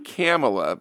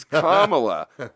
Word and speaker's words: Kamala: Guy said it's not Kamala because K-A, you know, it sounds like capitalism. It's Kamala, like Kamala. The C-O in communism. Kamala, communism, not Kamala, capitalism.